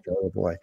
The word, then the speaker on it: Charlevoix: And Angela also serves on the board of the Charlevoix, did I say that right? – You did. – Charlevoix Area Humane Society, and volunteers with the Elk Country Animal Shelter.